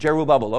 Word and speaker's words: Jerubbaal: Same as, how do you say Jerubbaal? It's two words, Jerubbaal, okay.